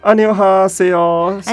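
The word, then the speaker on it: 안녕하세요: 안 녕 하 세 요，是不是这样？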